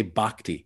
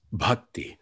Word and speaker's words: bhakti: And see, this is why I'm dialoguing with Darren, because he doesn't say bhakti, he says bhakti, the true pronunciation.